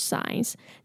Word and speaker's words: signs: And body language signs. 这个 signs, 这个 G 呢就不用发音了 okay?